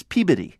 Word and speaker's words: Peabody: It's not like the character in Bullwinkle. It's Peabody.